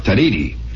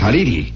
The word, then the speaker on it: Tariri: It's pronounced Tariri. No, the pronunciation is very important. It's not a Spanish word. That's a Chapa word, Chapra, rather. It's a Chapra Indian name, and it's not Tariri.